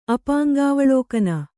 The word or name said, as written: ಅಪಾಂಗಾವಲೋಕನ